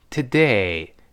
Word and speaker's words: today: 如果我们在生活中一带而过呢，它另外一个发音叫 today today。第一个由 o 变成 a t today。这里我们读成 today 就好了。注意 t 重轻读 day 重读。